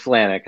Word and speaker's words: Flajnik: It's Flanek. It's Silent J.